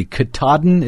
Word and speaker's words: Katahdin: The Katahdin, is that how you say it? – Yes.